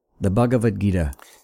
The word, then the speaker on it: Bhagavad Gita: The Bhagavad Gita.